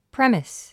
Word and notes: premise: prémis; プレミス

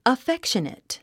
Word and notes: affectionate: əfékʃənət; アフェクショネト

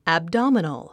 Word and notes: abdominal: æbdάmənl; アッブドゥミノゥ

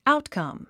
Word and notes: outcome: áutkʌ̀m; アウトカァム

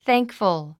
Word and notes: thankful: θǽŋkf ə l; サンクフゥ

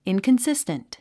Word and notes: inconsistent: ìnkənsíst ə nt; インコンスィステント

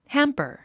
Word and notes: hamper: hǽmpə r; ハンパァ

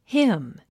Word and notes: him: hím; ヒム

